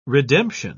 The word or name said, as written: redemption